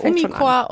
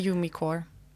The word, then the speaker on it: Umicore: In Deutschland sagen wir Umicore und weltweit sagen wir Umicore.